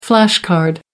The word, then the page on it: flash card: American English